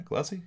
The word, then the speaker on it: Gulacsy: Am I saying that right? Gulacsy? – Yeah. Oh, yeah, sir.